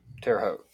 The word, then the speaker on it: Terre Haute: Terre Haute.